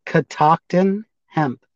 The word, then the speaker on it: Catoctin Hemp: So it's Catoctin Hemp.